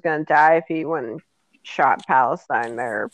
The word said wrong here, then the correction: Palpatine: Not Palestine. Palpatine.